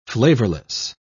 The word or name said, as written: flavorless